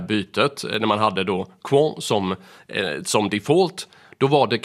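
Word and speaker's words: Qwant: Sen så har vi också Europaparlamentet, och det där var ganska kul för de har nyss bytt ut Google mot franska Quant. Jag var faktiskt tvungen att fråga äldsta sonen hur man skulle uttala det där, men det ska vara Quant.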